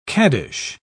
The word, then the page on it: caddish: British English